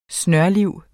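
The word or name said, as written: snørliv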